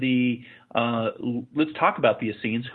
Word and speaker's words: Essenes: The Essenes? – The Essenes.